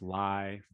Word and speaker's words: Ley: That's pronounced lie for you. Most of you who are my friends are still 17 years later, still don't call me lie.